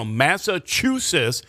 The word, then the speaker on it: Massachusetts: And this one I laugh is Massachusetts, and it's with the stress on the third syllable. Chew, so Massachusetts, that's how you say it.